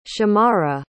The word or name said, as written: shamarra